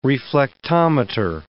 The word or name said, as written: reflectometer